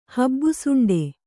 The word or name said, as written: ಹಬ್ಬುಸೊಂಡೆ